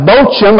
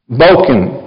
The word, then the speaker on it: Bochim: It looks like Bochim up there on the screen, but it's actually pronounced Bochim.